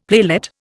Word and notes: playlet: Indian

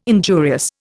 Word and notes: injurious: Indian

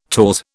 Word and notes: taws: British English

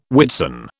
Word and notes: Whitsun: British English

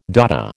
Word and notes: Dada: American English

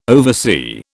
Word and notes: oversee: British English

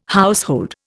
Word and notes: household: Indian

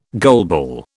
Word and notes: goalball: British English

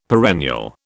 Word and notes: perennial: British English

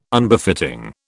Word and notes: Unbefitting: British English

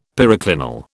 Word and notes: periclinal: British English

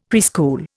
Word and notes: preschool: Indian

